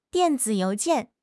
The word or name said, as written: dian4 zi3 you2 jian4